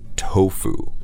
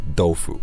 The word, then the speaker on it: tofu: So just be careful about the pronunciation here, guys, because our Western pronunciation is kind of more like the Japanese pronunciation. – Mm. – Tofu. – Yes. – Right? Tofu. It's not going to be, you know, dofu.